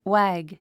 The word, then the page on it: wag: wǽɡ; ワァッグ